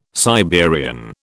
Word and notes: Siberian: British English

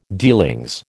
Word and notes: dealings: American English

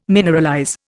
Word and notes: mineralize: Indian

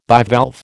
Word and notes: bivalve: American English

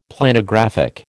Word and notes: Planographic: American English

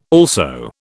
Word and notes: also: British English

